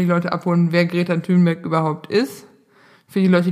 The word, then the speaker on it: Thunberg: Ähm, um ehrlich zu sein, meine, die erste große Revelation für mich bei der Recherche für diese Folge war, es heißt Greta Thunberg und nicht Thunberg.